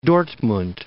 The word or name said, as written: Dortmund